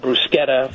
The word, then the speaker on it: bruschetta: saying bruschetta instead of bruschetta for the rest of their lives, and it's all my fault. – Wait a minute. So how do you say that one? – Bruschetta. – Oh, no. No. – CH no. – In Italian is a hard K, and there are absolutely no exceptions.